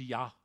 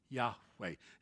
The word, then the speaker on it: Yahweh: It's Yahweh, Yahweh. His name is not Jehovah. There is no J or V in the Hebrew alphabet. They could not have pronounced it.